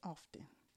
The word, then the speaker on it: often: Did you see how I said often? It's not often. – It's often. – Something is silent. It's often.